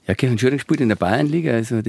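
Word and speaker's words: Kirchanschöring: Habe ich es richtig ausgesprochen? Grüß Gott. – Guten Morgen, ja, grüß Gott. Ja, natürlich, Kirch an Schöring ist richtig ausgesprochen.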